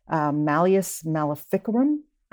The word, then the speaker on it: Malleus Maleficarum: Inquisition, and how a particular ancient religious sect got a hold of the book, The Hammer of Witches, Malleus Maleficarum, I guess is how it's pronounced. – You are pronouncing just as well as I can pronounce it. That sounds beautiful. I'm sure that's the way the Latin is pronounced.